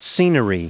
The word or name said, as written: scenery